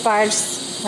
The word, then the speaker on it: sparge: Because sparge rhymes with charge, when I would brew at home, I would scream, sparge!